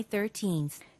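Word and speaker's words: thirteen: July 13th. – 자, July 13. 뒤에가 길게 끌리는 건 13이 맞고요.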